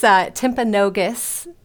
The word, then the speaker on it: Timpanogos: Let's go ahead and get started and learn a little bit about your school, Timpanogos High School. Did I say that correctly? – So close. It's Timpanogos High School.